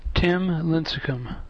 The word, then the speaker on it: Tim Lincecum: Tim Lincecum.